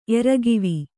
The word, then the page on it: ಏರುಗಾವು: Kannada